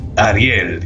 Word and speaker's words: Ariel: The way you pronounce it in Spanish is Ariel, right? – Ariel. – Ariel, yes, I am from,